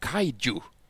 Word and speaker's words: kaiju: och som jag oftast uttalar fel, och det är därför vi har Niklas till hjälp här. Men det handlar om stora monster, för vi ska nämligen prata om kaiju. Sa jag rätt? Snyggt!